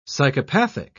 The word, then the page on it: psychopathic: British English